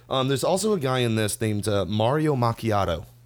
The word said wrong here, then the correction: Machado: I think is how you say his name. Or Machado. – Not to be confused with Luigi Cappuccino. – Sorry, Mario Machado. – Oh, Machado, excuse me.